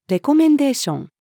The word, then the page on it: レコメンデーション: female